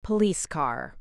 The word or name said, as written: police car